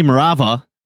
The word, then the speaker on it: Marawa: And I will say, first of all, before I forget, Benny is Marawa. Am I pronouncing that right, Benny? – It's pronounced Marava. The W is Marava. Pronounced like a V, but I've accepted both at this point.